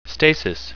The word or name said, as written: stasis